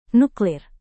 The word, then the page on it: nuklir: Indonesian